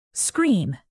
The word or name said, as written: screen